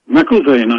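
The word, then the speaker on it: McIlvaine: That's for Thomas Garrett Isham, a living author who wrote the biographies of Charles Pettit McIlvaney and Augustus Toplady. By the way, is it McIlvaney or McIlvain? – McIlvain, I believe. – Okay, well, I've been mispronouncing it then.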